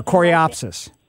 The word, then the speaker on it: coreopsis: I have some, I guess you pronounce it, coreopsis or coreopsis? – Yeah, coreopsis.